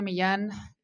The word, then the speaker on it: Millan: And then my blog, it's Carolina Millan. It's spelled with double L.